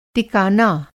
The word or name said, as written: टिकाना